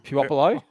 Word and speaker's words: Puopolo: Yeah, do you know how to pronounce them? – Puopolo. – Yeah, good work.